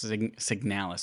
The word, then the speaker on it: Signalis: The emphasis is definitely on the second syllable. And they say sig as in signal. The second one as emphasized in canal. So signalis, I guess. And is as in kiss. Yes, signalis. So Alex said it correctly.